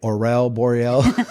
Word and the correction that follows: Aurora Borealis: Aurora Borealis.